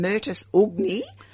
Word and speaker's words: Myrtus ugni: It's got a funny name, Myrtus ugni. Yes. That's, if you know Latin, that's how you would pronounce it. – Oh. – U-G-N-I, so in the myrtle family.